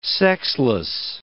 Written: sexless

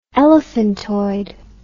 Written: elephantoid

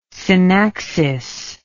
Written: synaxis

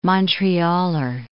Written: Montrealer

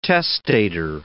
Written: testator

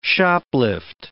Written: shoplift

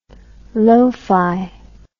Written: low fi

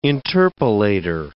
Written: interpolator

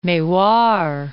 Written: Mewar

